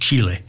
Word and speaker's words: Chile: Or as they say on the ABC, Chile. Because their pronunciation guide is right. It's pronounced Chile.